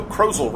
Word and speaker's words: Krozel: Monday, April 27, by Joe Krozel, rhymes with puzzle.